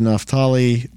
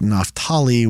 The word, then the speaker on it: Naphtali: I am not Jewish, don't speak Hebrew natively, but every time I've heard people mention the name, it sounds more like Naphtali when native speakers and people say it than Naphtali. But hey, I'm from Texas, and if I want to butcher it and call it Naphtali, I can do it however I want, because I'm from Texas, and we make a religion out of butchering other people's words.